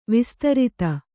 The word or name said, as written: ವಿಸ್ತರಿತ